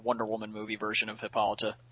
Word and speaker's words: Hippolyta: Hippolyta? – Hippolyta. – Hippolyta, sorry.